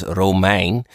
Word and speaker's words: Romijn: And I was triggered because it's a Dutch name, actually pronounced in Dutch as Romijn.